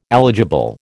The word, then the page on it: eligible: American English